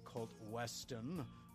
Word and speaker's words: Kolt Weston: HavocRaines, and also senior referee Kolt Weston.